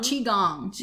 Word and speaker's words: Qigong: Now, okay, you wrote an amazing paper helping cancer patients cope with uncertainty and illness through, is it pronounced Qigong? – Qigong. – Yes.